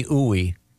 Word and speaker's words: Ooi: I swear to God, it says, I'm not kidding, Dr. Stephanie Ooey. It's spelled O-O-I. – Sea Cruise, right? – Yeah. Mm-hmm. Ooh-wee, baby. Yeah. Ooh-wee. Dr. Stephanie Ooey, there's a pronouncer here, and it says it rhymes with gooey.